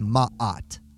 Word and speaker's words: Maat: But in my Egyptology class, it was Maat, like a silent H broke up the two vowels.